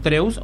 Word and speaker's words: Drews: ¿Pronuncié bien tu apellido, Drews o Drews? – Sí, pronunciaste muy bien, sí, D-R-E-W-S.